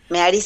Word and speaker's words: Mehari: Meari se escribe M-E-H-A-R-I, Aventuras en Meari.